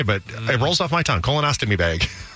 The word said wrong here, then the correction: colostomy: How do you say it again? – Colostomy.